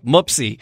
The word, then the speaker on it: Mupsie: And that was, I was going to say, how do you pronounce that? It's like Mupsy.